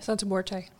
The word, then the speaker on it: Santa Muerte: Santa Muerte.